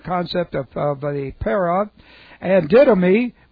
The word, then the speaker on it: didomi: And then didomi, D-I-D, long O-M-I.